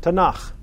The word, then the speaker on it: Tanakh: So you take the T, the N, and the K, and you end up with a word which is called, and they pronounce it, Tanakh.